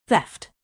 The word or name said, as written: theft